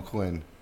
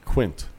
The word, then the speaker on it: Quint: They called him Quinn. – Quint. – You going in the water? – Quinn, you're not going to do this on the... – They're saying Quint, but you're not hearing the T.